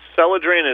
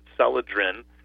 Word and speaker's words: Celadrin: It's Selodrin is spelled C-E-L-A-D-R-I-N, Selodrin.